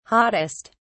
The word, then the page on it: hottest: British English